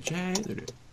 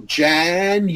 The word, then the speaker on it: January: January. Okay. – So, four syllables. Watch me. Jan-u, like you. There's a Y. January.